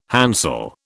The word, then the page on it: hansel: British English